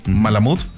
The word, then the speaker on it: Malamute: Dice, buenos días Bésame, buenos días doctor, tenemos una Alaska Malamute. – Malamut. – Tenemos una Alaska Malamut de 7 meses.